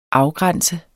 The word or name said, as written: afgrænse